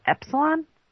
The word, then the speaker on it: Epsilon: Epsilon? – Yeah, that's it.